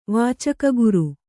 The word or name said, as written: ವಾಚಕಗುರು